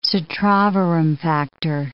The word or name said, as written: citrovorum factor